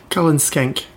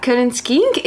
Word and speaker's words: Cullen Skink: Dis-moi si je prononce bien, c'est une soupe qui s'appelle le Cullen Skink. – Cullen Skink. – Voilà, Cullen Skink.